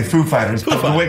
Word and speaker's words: Foo Fighters: And he comes up, and he's like, he asked us if the accent was on Foo or Fighters? And we know who he is. Like, of course, we know how he speaks. And we said, the accent is on Fighters, actually. So he goes up, he's like, ladies and gentlemen, Foo Fighters.